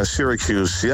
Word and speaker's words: Syracuse: Wat spreek je dat mooi uit, ja, Syracuse.